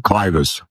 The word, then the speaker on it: clivus: Am I pronouncing that correctly? – Clivus. – Thank you.